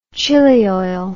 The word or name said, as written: chili oil